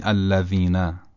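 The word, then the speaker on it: الذين: مثلا در کلمه الذین، حرف زال رو خیلی خودمونی بخوایم تلفظش رو توضیح بدیم، بعد بگیم تو که زبونی تلفظ میکنیم، رزه رو تو که زبونی تلفظ میکنیم، یعنی نوک زبانمون رو یه مختصری از بین دندونهای بالا و پایین بیرون میاریم، یه مختصر.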